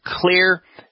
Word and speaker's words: Xlear: called Clear Nasal Wash. And Clear is spelled X-L-E-A-R. That X stands for xylitol.